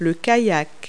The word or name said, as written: kayak